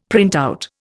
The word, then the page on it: printout: Indian